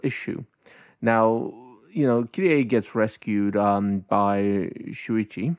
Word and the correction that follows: Shunichi: Shunichi? What was it? How do you say his name? – Shunichi. Who cuts her hair.